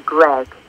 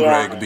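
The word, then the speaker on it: Greig: It's Tamsin Grieg, to rhyme with Greg.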